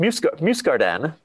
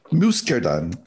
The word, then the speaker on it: Muscardin: Am I saying that right? – Almost. Muscardin. – Muscardin.